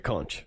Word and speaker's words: conch: So then Ralph and Piggy go to confront them with was it the haunch? Is that what they call it? – The conch. – Conch, sorry. – Or conch. Everybody pronounces it. It's they say conch in the film. – It's C-O-N-C-H. I'm not actually sure. It just looks like the word would be conch, and that's what... It's a shell. – It's a big seashell. – Yeah, I think it's actually can be pronounced either way. People can learn from this.